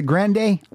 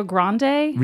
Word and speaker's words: Grande: But I would say Grande. – Anything. – I would say Grande. – Do you see how like Ariana Grande.